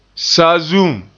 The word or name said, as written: saazuum